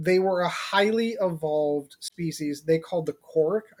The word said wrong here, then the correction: Krork: Excuse me, it's pronounced Krork. K-R-O-R-K.